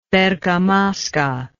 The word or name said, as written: bergamasca